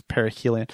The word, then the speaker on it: perihelion: I always want to say perihelion, but I think it's perihelion, and it's the closest point in its orbit to the sun.